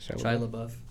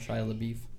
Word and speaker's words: Shia LaBeouf: Is that how you say it? – Shia LaBeouf. Some people like to call him Shia LaBeef.